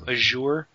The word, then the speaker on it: Azure: Xbox One could eventually offer backwards compatibility using its Azure. Is that how you say it? – Azure cloud servers.